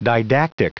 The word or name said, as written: didactic